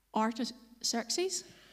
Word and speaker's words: Artaxerxes: Artaxerxes.